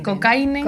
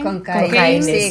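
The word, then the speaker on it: Cockayne: Síndrome COCAINE. – COCAINE. Sí, COCAINE. Sí, nosotros hemos tenido que aprender a pronunciarlo, sí, que era la primera vez que lo escuchábamos.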